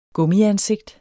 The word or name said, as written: gummiansigt